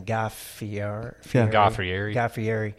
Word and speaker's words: Guy Fieri: Guy Fieri.